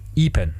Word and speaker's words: E-Pen: E-Pen? – E-Pen, ja. – E-Pen. Ist das also I oder I? – I wie das englische E ausgesprochen.